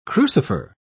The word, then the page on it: crucifer: British English